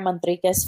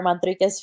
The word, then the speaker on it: Manriquez: And my Facebook page is Sarah Manriquez Photography. And my Instagram page is Sarah Manriquez Photography.